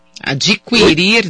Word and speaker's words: adquirir: Não, nunca, nunca se pronuncia o u. adquirir, eu adquiri, você adquirirá, extinguir, ele extinguiu, ele distinguiu, sem a pronúncia do U, nos verbos adquirir, distinguir e extinguir.